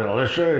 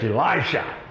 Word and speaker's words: Elisha: It's not Elisha, it's Elisha. Elijah and Elisha. Just the last three letters are pronounced different.